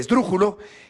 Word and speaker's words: Esdrújulo: ese toro de Dearo de nombre Esdrújulo, que llevaba el acento, llevaba el acento en la antepenúltima sílaba,